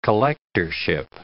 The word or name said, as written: collectorship